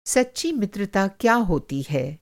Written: मित्रता

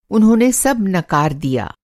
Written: नकार

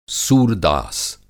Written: सूरदास

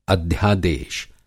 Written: अध्यादेश

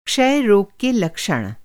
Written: क्षय

